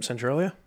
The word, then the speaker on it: Centralia: So what I call this drink is a Centralia, right? And everyone in this area is familiar with, and I think that's how you pronounce it, right? – Yep, Centralia. Yeah.